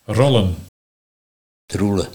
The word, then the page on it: Troelluh: Nieuw lekkerlands